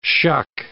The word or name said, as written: shuck